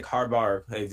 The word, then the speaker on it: Harbaugh: And, you know, guys like Harbaugh, that's how you pronounce his name, for the Ravens head coach, he was a special teams coach prior to the Ravens.